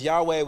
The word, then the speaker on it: Yahweh: which we pronounce as Yahweh, which our English puts as all caps, the Lord.